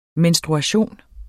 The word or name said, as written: menstruation